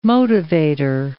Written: motivator

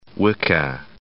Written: Wicca

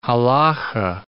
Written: halakha

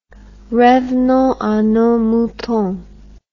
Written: revenonsanosmoutons

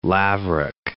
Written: laverock

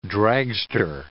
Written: dragster

